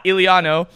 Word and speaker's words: Iuliano: We have Caleb Iuliano.